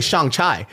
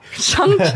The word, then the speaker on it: Shang-Chi: I, I, was mispronouncing it the whole time. I was calling it shang Chai, Shang-Chi.